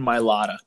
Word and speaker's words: Mailata: How do you pronounce it? Mailata? Is that what it is? – Jordan Mailata. Nice guy.